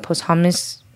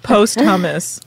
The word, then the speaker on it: posthumous: posthumous. – Yeah. – Posthumous. Yeah. Posthumous. – I like just post hummus.